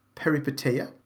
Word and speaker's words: Peripeteia: jag kanske säger det här helt fel, men Peripeteia. – Ja, låter rätt.